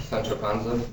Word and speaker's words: Sancho Panza: Sancho Panza? – Yeah. You didn't have the R there. You can pronounce the consonant wrong, but you still have to have, you can't just like drop a consonant.